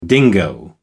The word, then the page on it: dingo: American English